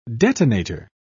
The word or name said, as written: detonator